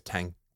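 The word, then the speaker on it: Tháng: This is, and by the way, I was terrified because, as you know, this is exactly on the corner of Street 1 Thang 4 and good. Thang. I think it's Tang.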